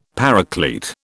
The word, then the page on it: Paraclete: British English